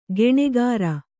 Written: ಗುಣಕಾರ

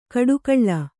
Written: ಕೊಟ್ಟೆಗೋಳಿ

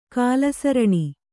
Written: ಕಾಲಸರಣಿ

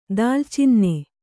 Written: ದಾಲ್ಚಿನ್ನೆ

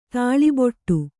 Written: ತಾಳಿಬೊಟ್ಟು

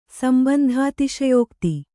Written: ಸಂಬಂಧಾತಿಶಯೋಕ್ತಿ

